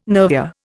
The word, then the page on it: nervure: Indian